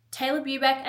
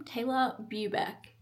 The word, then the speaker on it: Taylor Bubeck: Taylor Bubeck admits, that's how you say my name. Everyone's like, how do you say your name? Taylor Bubeck, you're welcome.